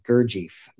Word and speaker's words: Gurdjieff: That'd be, uh, help me out with pronunciation, Gurdjieff group.